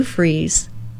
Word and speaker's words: Fries: And this is the Sue Freeze Show, Sue Freeze. Spelled like fries, one word, .com.